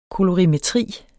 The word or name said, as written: kolorimetri